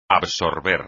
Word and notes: absorber: espagnol

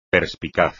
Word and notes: perspicaz: espagnol

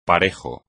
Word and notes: parejo: espagnol

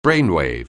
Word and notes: brainwave: anglais

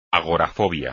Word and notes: agorafobia: espagnol

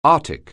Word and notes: arthritis: anglais

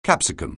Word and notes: capsicum: anglais

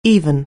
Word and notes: even: anglais

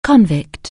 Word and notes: convict: anglais

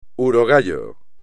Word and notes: urogallo: espagnol

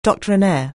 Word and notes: doctrinaire: anglais

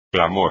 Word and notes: clamor: espagnol

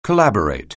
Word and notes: collaborate: anglais

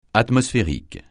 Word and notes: atmosphérique: francais

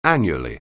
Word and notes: annually: anglais

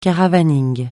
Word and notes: caravaning: francais

